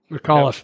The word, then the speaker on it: McAuliffe: McAfee, or I can't remember how to say his name, but Terry McAuliffe. – That guy. McAuliffe, yep.